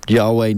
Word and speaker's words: YHWH: I think, yeah, Yahweh Nalgun, which I didn't know that was how the it's because it's spelled YHWH Nalgun, but you know, when you put it in the biblical context, it's pretty funny.